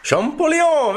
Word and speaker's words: Champollion: Champollion, very nice.